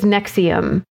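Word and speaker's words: NXIVM: which is Nexium. And if you're not familiar with it and you feel like Googling it, it's NXVIM is how it's spelled.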